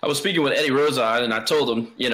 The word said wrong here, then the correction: Rojas: know, or it's a Rojas. Am I saying that wrong? – Yeah, Rojas, yep. – Rojas, my bad.